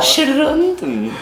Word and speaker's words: Geronton: que é, nem sei como é que fala, né? Não, não, que é o 2014 foi 2014. o Geronton. Geronton. – Fala, fala, Geronton. É é, é, é assim pronuncia.